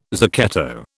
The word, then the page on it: zucchetto: British English